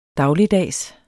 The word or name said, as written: dagligdags